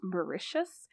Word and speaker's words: Mauritius: They plan a second trip to, and Wikipedia tells me it's pronounced Mauritius, which is an island nation in the Indian Ocean, which I learned about today.